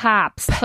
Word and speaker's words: Papst: Papst. – Papst, con una A alargada.